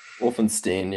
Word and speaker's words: Wulfenstein: James, I meant to ask you your pronunciation of your last name. James Wolfenstein? – Wolfenstein, yeah.